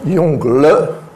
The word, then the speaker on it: Yongle: Alors, ça se prononce Yongle, pas les, Yongle, hein, en pinyin,